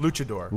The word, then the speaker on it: luchador: Luchador.